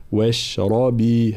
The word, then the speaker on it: واشربي: في قوله تعالى فكلي واشربي، طبعا هنا واضح كلمة واشربي راء مفخمة، بقية الحروف مرققة، فلا نقول واشربي واش، لا واشربي هكذا.